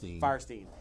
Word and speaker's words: Fierstein: She did Harvey Firestein from, am I pronouncing his name right? Is it Firestein or Fierstein? – Firestein.